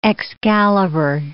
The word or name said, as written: Excalibur